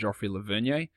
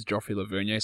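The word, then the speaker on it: Joffrey Lauvergne: And okay, now I've been trying to work out, I've always just pronounced his name as Joffrey Lavernier. Never heard anyone else say it that way, so I've transitioned into Laverne.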